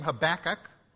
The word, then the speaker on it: Habakkuk: And so just for sake of choosing one, I grew up with the name Habakkuk. And so emphasis on the back, and that's how I'm going to pronounce it.